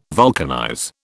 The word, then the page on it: vulcanize: British English